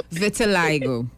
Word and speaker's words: vitiligo: Can you pronounce the word for me? Because I hear so vitiligo. – Because I hear so many different pronunciations, you know.